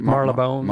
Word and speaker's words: Marylebone: And also, I was walking around yesterday over by Marylebone. Is that how you say it? – Marylebone. – Marylebone.